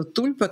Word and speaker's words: тульпа: На одном из русских сайтов говорится, что тульпа, как правильно говорить, тульпа, кстати говоря? – Тульпа, да.